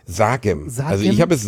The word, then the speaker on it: Sagem: Sagem.